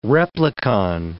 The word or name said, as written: replicon